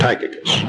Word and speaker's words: Tychicus: When we get to the name in the song for that particular chapter, I think you will find that it sings better if you make the while into a long I sound. Make it Tychicus.